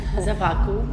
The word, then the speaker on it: Zavakou: Zavakou.